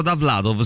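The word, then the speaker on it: Dovlatov: Si pronuncia Da Vlatov, sì, però si scrive con due O, diciamo, esatto.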